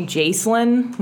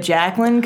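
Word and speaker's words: Jaclyn: Yeah, well, people, like, waiters and stuff will call me Jacelyn when they see my credit card instead of Jacqueline, because it's J-A-C-L-Y-N.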